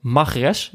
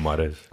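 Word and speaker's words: Mahrez: Magres, heb ik begrepen dat het tegenwoordig eh, zo uitgesproken dient te worden. Ja, ik zeg gewoon Mares.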